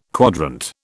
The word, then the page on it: quadrant: British English